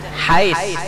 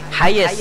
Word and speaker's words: Hiace: Hiace kan apa bacanya kita? Tulisannya Hiace, Bang. Apa bacanya? Hais. Hayes. Han, lain tulisan, lain bacaan.